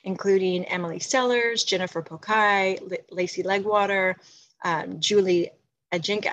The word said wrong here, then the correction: Ajinkya: I always slightly mispronounce her name. I'm sorry, Julie Ajinkya, and Leanne Davis, who were our superstars working on this initiative.